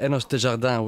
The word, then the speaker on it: Enos Desjardins: Almost, yeah. Enos Desjardins would be the right, way.